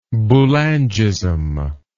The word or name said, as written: Boulangism